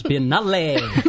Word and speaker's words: Biennale: Maddie, it's pronounced Biennale.